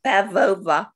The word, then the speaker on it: Pavlova: And you say that Jill thinks Kendall is an amazing dancer, like Anna Pavlova. How do you say that? – Pavlova.